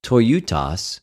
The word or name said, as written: τοιουτος